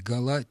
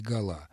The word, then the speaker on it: гола: Но приводится пример, который читается так. Забили два гола и в скобках гола. То есть в сочетании с этими числительными 2, 3 и 4 возможны оба варианта. Забили 4 гола или забили 4 гола.